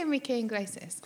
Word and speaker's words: Enrique Iglesias: All the organs of how do you say Enrique Iglesias? – Like that. – Yeah. Okay, all the organs of Enrique... – I'll say the name. Okay. – Okay. All the organs of Enrique Iglesias.